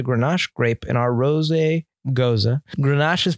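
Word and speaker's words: Gose: Two roads is driving a tanker truck down the road less traveled with this Rosé Gozé, pronounced Goza. – Never mind. Let me redo. I've been calling it Rosé Gozé this whole time. – You have. – Screw it. I just really like calling it Rosé Gozé, but it's a Goza, not a Gozé.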